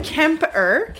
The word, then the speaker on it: Kemper: Today's episode is about the gruesome and horrific Ed Kempner. – That's not his name. – You mispronounced it. – Ed Kemper. – Kemper.